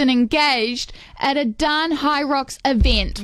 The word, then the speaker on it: Hyrox: You said something about Hydrox. How do you say it? – Hyrox. Hybrid rock star.